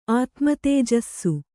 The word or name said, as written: ಆತ್ಮತೇಜಸ್ಸು